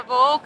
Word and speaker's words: Vouk: Hi everyone, Ira Voke.